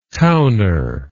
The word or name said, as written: towner